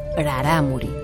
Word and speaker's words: rarámuri: La denominación tarahumara es la castellanización de la citada palabra rarámuri, que debe pronunciarse con una R suave al principio, rarámuri, inexistente, ya que en este último caso se deformaría la pronunciación original sin R fuerte.